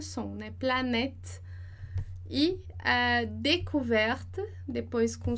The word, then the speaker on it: découverte: D com som de E, découverte. D, E, por causa do acento, então, découverte. E o último E que é mudo porque ele não tem acento.